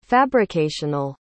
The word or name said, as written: fabricational